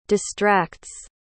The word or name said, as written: distracts